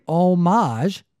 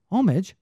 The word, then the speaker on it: homage: And if you say homage, you are referencing art. – Yes. – If you say homage, you are like paying tribute.